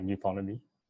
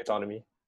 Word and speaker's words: nuTonomy: Wait, wait, wait, by, by the way, you might want to re-record that as like nuTonomy. nuTonomy? Oh, sorry, yeah. sorry. – Yeah. Sorry, pronounce it nuTonomy.